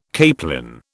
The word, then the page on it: capelin: British English